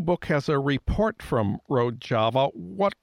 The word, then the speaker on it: Rojava: Is that, am I pronouncing that right? – Rojava, yeah.